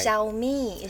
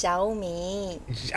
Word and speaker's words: Xiao Mei: Because, well, my name can be pronounced like two ways or three ways. One is the proper Chinese Mandarin way, which is Xiaomi.